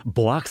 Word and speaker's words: Boak: Boak sa to vyslovuje tam, čo je napísané? – Áno, áno, áno.